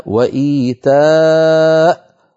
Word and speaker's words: وإيتاء: أيضا المثال الثالث في هذه الصفحة وإيتاء ذي القربى في سورة النحل، نقف عليها وإيتاء ولا تنطق الياء أبدا لأنها كرسي للهمزة.